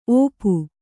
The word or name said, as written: ಓಪು